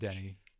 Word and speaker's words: Denis: Looking at how to pronounce with the typical French Canadian or French pronunciation, Denis Villeneuve. Do not pronounce the S to Denis, it is silent. – I was right about Denny. – Do not say Denis.